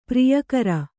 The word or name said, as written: ಪಿರಿಯಕ್ಕರ